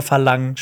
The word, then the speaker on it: Farang: Es wird Farang geschrieben, aber Verlang ausgesprochen.